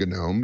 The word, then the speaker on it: GNOME: I believe that's pronounced GNOME because it has a G in front of there, and they always like G things.